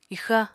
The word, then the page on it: ixa: apatani